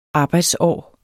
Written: arbejdsår

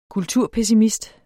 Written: kulturpessimist

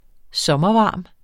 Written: sommervarm

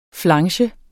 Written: flange

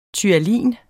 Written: ptyalin

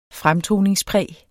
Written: fremtoningspræg

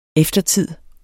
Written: eftertid

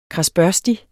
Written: kradsbørstig

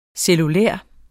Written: cellulær

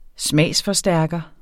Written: smagsforstærker

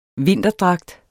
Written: vinterdragt

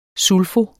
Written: SULFO

